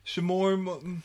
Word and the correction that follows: Lamorne: I think it's Lamorne. – That's why I said Lamorne. – Lamorne. It looks like Lamorne. – Yeah, Lamorne Morris.